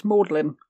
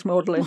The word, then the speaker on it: Magdalen: In Oxford, Magdalen is pronounced Maudlin. – That's Maudlin? I've always wondered. Because when you look up Oxford, Maudlin doesn't show up anywhere. – No, it's Magdalen is Maudlin. Maudlin. Only in Oxford.